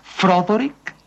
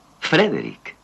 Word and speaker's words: Frederick: ¿Dice usted también Froderick? – No, Frederick. – ¿Y por qué no es Froderick Frankenstein? – Porque no, es Frederick Frankenstein.